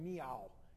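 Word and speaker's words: Miao (苗): In Chinese, they call them Miao. It sounds like a cat. If I say it right, that's how they say Hmong in Chinese.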